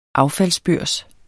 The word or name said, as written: affaldsbørs